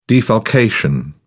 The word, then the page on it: defalcation: British English